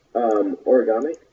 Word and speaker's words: Origami: Origami. O-R-I-G-A-M-I-N-C.